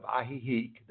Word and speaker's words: Ajijic: We landed in the state of Jalisco in the small town of Ajijic, that's spelled A-J-I-J-I-C. It's an indigenous word, it's not a Spanish word.